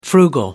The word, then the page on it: frugal: American English